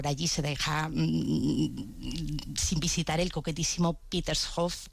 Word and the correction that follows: Petersfriedhof: eh, perdón, Petersfriedhof, vamos a pronunciarlo bien,